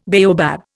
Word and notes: baobab: Indian

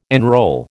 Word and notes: enrol: American English

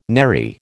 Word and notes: nary: American English

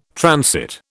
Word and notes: transit: British English

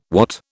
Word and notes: wot: British English

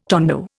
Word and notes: tondo: Indian

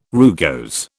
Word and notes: rugose: British English

